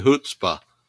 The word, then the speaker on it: chutzpah: How do you really define what business are you in, and what is marketing chutzpah? And I'm hoping I pronounce that right. – Yeah, no, you did.